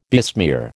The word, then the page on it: besmear: American English